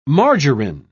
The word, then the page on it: margarin: British English